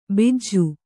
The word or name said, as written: ಬಿಜ್ಜು